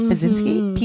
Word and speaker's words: Pedzinski: And it's Wanda Pudzinski. Is that how you say it? Pudzinski. P-E-D-Z-I-N-S-K-I. – That's right.